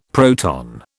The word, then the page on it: proton: British English